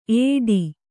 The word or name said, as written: ಏಟಿ